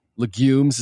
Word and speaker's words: legumes: Legumes, is that how you pronounce it? How do you pronounce it? – Legumes. – And technically, kind of more emphasis on the U, legumes.